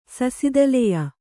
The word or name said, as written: ಸಸಿದಲೆಯ